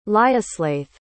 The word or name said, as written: lioslaith